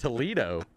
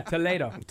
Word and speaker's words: Toledo: The answer that you did not get, I believe it's pronounced just Toledo. – Yeah, Toledo.